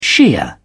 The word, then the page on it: shia: British English